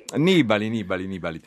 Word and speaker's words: Nibali: Nibali.